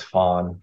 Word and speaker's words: Phan: So my birth name is Fawn, P-H-A-N.